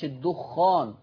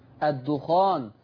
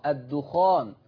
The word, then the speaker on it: الدخان: وبالمناسبة مش الدخان زي ما في ناس بنطقها غلط، الدخان بتخفيف الخاء، الدخان، ماشي الكلام؟